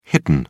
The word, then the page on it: hidden: British English